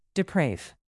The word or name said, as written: deprave